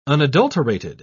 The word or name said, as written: unadulterated